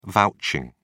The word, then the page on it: vouching: British English